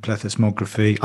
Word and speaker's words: plethysmography: with the more bells and whistles approaches of, of things like DEXA, MRI, or even good old water displacement, plethysmography. I actually said that right.